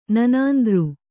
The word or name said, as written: ನನಾಂದೃ